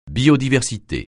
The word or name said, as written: biodiversité